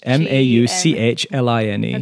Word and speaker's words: Mauchline: M L O T H M A U C H L I N E.